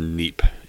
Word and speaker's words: Nheap: After that, I think this is pronounced Neep, N-H-E-A-P, Neep XP.